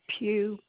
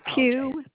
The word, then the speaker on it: Pugh: Nancy, N-A-N-C-Y, And the last name is Pugh, P like Paul, U-G-H. – I'm sorry, I'm going to need you to spell that last name again. – Pugh, P like Paul, U-G-H, like okay.